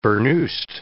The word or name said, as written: burnoosed